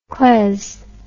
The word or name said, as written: claes